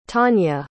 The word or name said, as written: tawnya